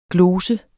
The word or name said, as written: GLOSERNE